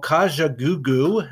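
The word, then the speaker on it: Kajagoogoo: And it's by a group called Kaja Goo Goo. And that's how it's spelled, as it sounds.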